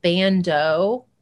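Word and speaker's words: ban.do: I think you pronounce it Bando. It's like B A N period D O.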